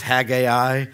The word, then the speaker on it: Haggai: This minor prophet is called Haggai. You may pronounce it as Haggai. That's okay. Either way, it's hard to find because it's real short.